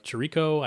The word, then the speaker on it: Chirico: Now, one interesting parallel that I found, there was an article written by Liz Chirico. I may or may not be pronouncing that correctly. – That sounds right to me. Yeah, we'll go with it.